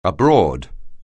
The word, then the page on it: abroad: anglais